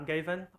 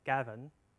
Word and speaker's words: Gavin: I'm Gavin or Gavin. Or even Gavin. This is the Cantonese pronunciation, so my colleague usually call me Gavin, but it's okay.